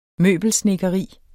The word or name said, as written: møbelsnedkeri